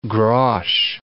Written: grosz

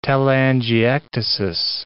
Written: telangiectasis

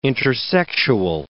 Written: intersexual

